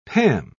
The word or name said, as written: pam